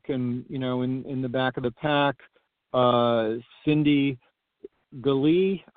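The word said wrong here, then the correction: Gallea: I believe, Galee, I always mispronounce Galley. – Her name, so I apologize. Galley, of course.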